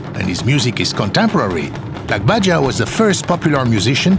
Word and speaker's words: Lagbaja: Here at Afropop Worldwide, we're big fans of Lagbaja, a fascinating Nigerian musician and performance artist who always appears masked in public.